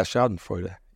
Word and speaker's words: Schadenfreude: Yeah, Schadenfreude. – Did I say it right? Yeah.